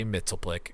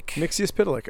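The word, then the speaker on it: Mxyzptlk: Is the actual correct pronunciation? Mitzis Pitalik. – Okay. – Mitzis Pitalik. – That's close to how I've always said it. – Yeah, no, it's not far off.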